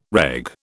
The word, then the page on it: reg: British English